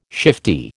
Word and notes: shifty: American English

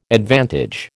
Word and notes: advantage: American English

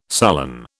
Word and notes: sullen: British English

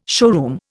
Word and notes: showroom: Indian

